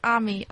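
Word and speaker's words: Aimée: I said Amy because it's spelled A-I-M-E-E, right? – Hi, guys. It's Ami. Ami like mommy.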